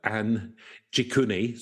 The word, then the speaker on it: Anjikuni: So we're going to be looking at Lake Anjunking. No, that's not how you spell it. Anjikuni, sorry. Anjikuni.